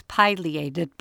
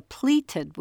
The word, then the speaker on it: pileated: I use pileated, but both pronunciations are equally correct. Use either as you wish. Just don't call it a pleated woodpecker, except in fabric stores near the ruffled grouse.